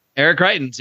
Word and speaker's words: Greitens: Is it Eric Greitens? – Eric Greitens. You got it right.